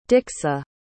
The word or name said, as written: dixy